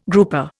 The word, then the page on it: grouper: Indian